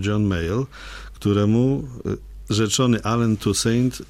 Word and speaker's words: Toussaint: jak właściwie go ci Amerykanie wymawiają. – Toussaint. – Toussaint mówią, to jak Toussaint, napisane jest?